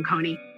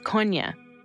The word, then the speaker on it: Kony: There are, it has to do with linguistic differences in southern Uganda, why people call him Kony. – The proper pronunciation is Konya.